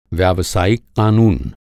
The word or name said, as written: व्यावसायिक